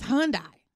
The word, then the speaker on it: Hyundai: It's Hyundai. I mean, well, how do you say it? – It's Hyundai. – Okay, Hyundai. – And you said it better that last time, but you were saying Hyundai.